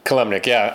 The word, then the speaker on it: Kilimnik: Konstantin Kilimnik. Am I saying that right? – Kilimnik.